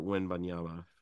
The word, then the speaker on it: Wembanyama: Okay, then we'll check in with Victor Wembanyama for the day.